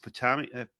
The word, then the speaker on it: Potomac: Rock Creek, Langston, and East Potomac.